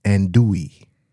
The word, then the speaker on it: andouille: but it's pronounced andouille, the sausage.